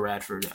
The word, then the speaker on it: Radford: Yeah, you can either or. Either or. Honestly, you can say RDFRD or Radford.